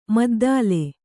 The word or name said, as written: ಮದ್ದಲೆ